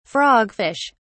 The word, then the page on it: frogfish: British English